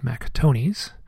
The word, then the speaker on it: Mac Tonnies: His name is pronounced Mac Tonnies, not Mac Tonnies.